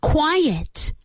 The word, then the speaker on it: quiet: Be quiet. Careful with the pronunciation. Quiet. Que no es lo mismo que bastante, que es quite.